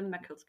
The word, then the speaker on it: Meckel's: Yeah, the upper jaw is the palatoquadrate cartilage and the lower jaw is the Meckel's cartilage. – You make that sound so easy. – The upper jaw is so much harder to say than the lower jaw. – I know. I can say Meckel's cartilage all day long.